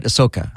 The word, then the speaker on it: Ahsoka: Ahsoka.